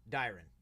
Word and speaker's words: Dyron: Dyron.